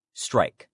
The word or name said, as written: strike